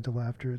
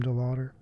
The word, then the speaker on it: DeLaughter: some people say DeLaughter, it's really Tim DeLaughter, spelled like DeLaughter.